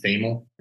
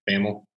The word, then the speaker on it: Thamel: Is it is it uh, Pete Thamel? Am I saying his name right? Thamel.